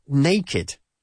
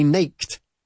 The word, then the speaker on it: naked: Pero siempre pronunciamos la ED como sílaba aparte. Another one you all know, of course, is naked. Yeah? We don't say naked, para desnudo.